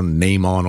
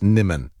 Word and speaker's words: Nimmin: If I go Nimon or something, God, it makes it sound like she's out of Star Trek. Somebody's texted saying it's pronounced Nimmin. And you know what? I'll go with that.